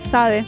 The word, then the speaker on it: Sade: Muy conocido también para otros que no saben cómo se pronuncia, Sade.